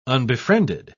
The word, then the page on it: unbefriended: British English